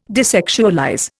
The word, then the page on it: desexualize: Indian